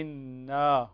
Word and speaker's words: إنا: فالإخوة الذين سيرسلون تلاوتهم انتبهوا الكلمة هذه، انتبهوا الكلمة هذه، يعني إنا يوجد فرق بين إن وإنا، هذه إنا ما الطبيعي، ليست مثل إن التي تنطق هكذا بدون مد، هذه فيها مد طبيعي.